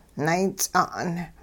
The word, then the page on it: nayts'a'n: hupa